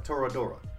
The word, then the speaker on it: Toradora: Have you guys ever heard of an anime called Todoroka? I think it's pronounced. No, sorry, Toradora.